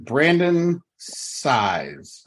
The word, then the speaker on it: Brandon Saiz: I'm your host and I'm here with Brandon Saiz.